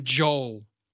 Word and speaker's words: Joel: But hearing my name, Joel, when you hear Americans say it, they're like, Joel? Door.